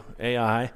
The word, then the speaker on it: Ai: Now they learn right away how important and how powerful this weapon was, because when they came to the city of Ai, that's how it's spelled, that's how you say it too, Ai, that was the only city where Israel failed to conquer them.